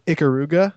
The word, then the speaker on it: Ikaruga: Number two, you're going to probably correct me on this, but I heard them talking about this game on their podcast, Ikaruga.